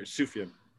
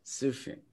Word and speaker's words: Sufjan: The silent J, my brother, Sufjan. – Sufjan.